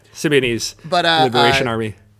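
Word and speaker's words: Symbionese Liberation Army: Symbian Lebanese Army, right? I know I butchered that, but... – Yeah, Symbianese Liberation Army.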